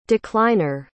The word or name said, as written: decliner